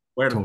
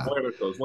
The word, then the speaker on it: Muertos: I Muertos. Totally butchered that. Yeah. – Muertos.